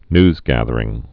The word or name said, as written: newsgathering